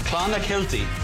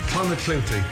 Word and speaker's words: Clonakilty: Clonakilty. – Clonakilty, thank you.